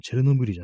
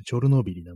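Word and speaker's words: チ ョ ル ノ ビ リ (Чорнобиль): そういうね、ところもありましてね、こう、まあ、最近の読み方があの、チェルノブイリじゃなくてチョルノビリなんていうね、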